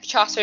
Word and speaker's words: Chaucer: Yes, it is Chaucer, it's like Geoffrey Chaucer, Canterbury.